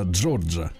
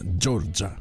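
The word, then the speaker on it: Giorgia: Товарищ э, э, Джорджа, и так правильно, Джорджа производит, mm-hmm.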